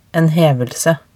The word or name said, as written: en hevelse